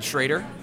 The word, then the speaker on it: Schrader: So it's James Edward Schrader, and Schrader spelled like Schroeder.